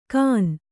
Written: ಕಾನ್

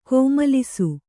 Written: ಕೋಮಲಿಸು

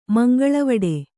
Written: ಮಂಗಳವಡೆ

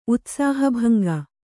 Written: ಉತ್ಸಾಹಭಂಗ

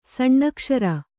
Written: ಸಣ್ಣಕ್ಷರ

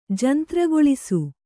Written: ಜಂತ್ರಗೊಳಿಸು

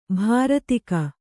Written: ಭೃತಕ

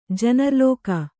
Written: ಜನರ್ಲೋಕ

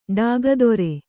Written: ಡಾಗದೊರೆ

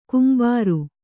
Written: ಗಂಭೀರ

